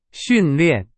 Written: 训练

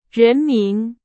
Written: 人民

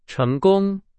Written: 成功

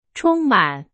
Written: 充满

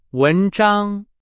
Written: 文章

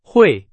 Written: 汇

